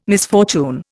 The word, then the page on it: misfortune: Indian